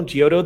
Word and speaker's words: Giotto: Saru uses the work of Giotto. I hope that's correct. The artist that gave birth to the Renaissance. Can you check me on that? G-I-O-T-T-O.